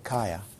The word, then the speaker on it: kaya: The word in Pali is kaya, K long A-Y-A, kaya.